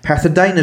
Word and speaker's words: pethidine: So in 1975, apparently, he was caught forging prescriptions of pethidine, is how you say it, for his own use, and he was actually fined for that as well, and actually briefly attended drug rehabilitation.